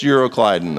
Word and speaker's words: Euroclydon: It's Euroclydon. That's exactly how you say it.